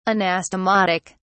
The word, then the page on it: anastomotic: British English